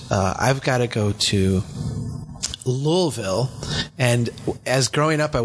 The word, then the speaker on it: Louisville: But I have some cousins that live there, and they said, no, that you just sound stupid when you say that. It's Louisville. Hmm. Which sounds stupid to me, but I'll accept it. You know, Louisville, that's how they say it.